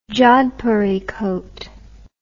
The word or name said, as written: jodhpuri coat